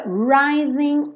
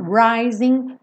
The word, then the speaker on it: rising: Rising frequentemente, 99,99% ,99 das vezes, um G uma palavra com ING não é pronunciada. São pouquíssimos sotaques no mundo que pronunciam esse G. Então, rising, rising of the sun.